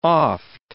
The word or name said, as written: oft